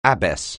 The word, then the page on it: abbess: anglais